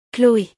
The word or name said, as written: Chloe